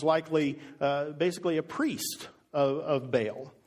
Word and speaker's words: Baal: You could have heard it pronounced Baal. I think the real technical meaning of it or pronunciation is something along the lines of Baal. So, lest you sit there and fret over a wrong pronunciation, I am aware that it's said in different ways.